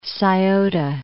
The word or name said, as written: Scioto